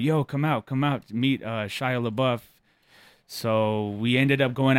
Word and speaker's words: Shia LaBeouf: Shia LaBeouf, how do you say his name? – Yeah, Shia LaBeouf,